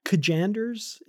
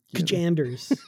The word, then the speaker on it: Kajanders: Well, we do have this other plot line, this, and I Kajanders, is that how we're saying his name? – Yes. – Or is it just Janders, silent K? – I like Kajanders. Like, Kajanders. Makes me think of like Mr. Bojangles.